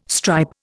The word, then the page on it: stripe: Indian